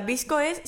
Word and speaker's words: VSCO: No, venga, Visco es, se escribe VSCO, ¿vale? Es que vi en el blog que se pronunciaba así, entonces ahora pues encima con el acento andaluz es como Visco.